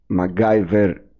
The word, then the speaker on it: MacGyver: Το ο MacGyver. MacGyver είναι η σωστή προφορά, το έχω ακούσει με διάφορους τίτλους.